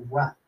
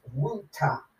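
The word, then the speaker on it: Rutte: Now, when you look at it, it almost looks like Rutt, but his last name is pronounced Ruta.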